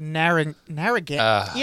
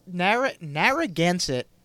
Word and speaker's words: Narragansett: The Narragansett. You know what? They are putting the N in the wrong place. It Narragansett.